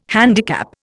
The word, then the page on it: handicap: Indian